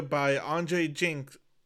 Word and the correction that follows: Jikh: Jink? Andrei Jikh. – Jikh. Okay.